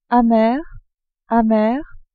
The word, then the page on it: amer amere: French